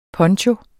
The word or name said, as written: PONCHOERNES